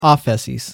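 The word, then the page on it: ἄφεσις: Greek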